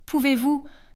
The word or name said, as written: pouvez-vous